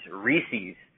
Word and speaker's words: Reese's: R-I-S-I is the spelling. It's Italian. The candy is Reese's Pieces, more like the name Reese.